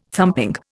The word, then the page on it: thumping: Indian